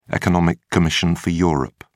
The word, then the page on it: economic commission for europe: British English